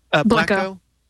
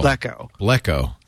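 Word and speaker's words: Blekko: I can't remember if Blacko has a slash tag.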